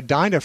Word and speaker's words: Dinah: It's pronounced Dina, and I'd like to thank you very much for your show. My name is a Hebrew name, which is why it's pronounced the way it is.